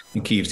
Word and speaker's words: Kyiv: So, I mean, we all say Kyiv instead of Kiev these days because Kiev's the Russian pronunciation and Kyiv's the Ukrainian pronunciation.